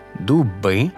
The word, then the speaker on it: дуби: Насамперед відмітимо, що багато іменників вимовляються тепер із наголошеним закінченням «доми», «дуби», «цехи».